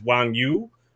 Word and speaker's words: Wang Yu: but he's Wong Yui, UA? And apparently, so anyways, the point here, my horrible mispronunciation aside, was that one of the ways that his name will be pronounced is Wang Yu.